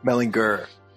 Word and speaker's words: Mellinger: Mellinger. Yeah, hard G, like me.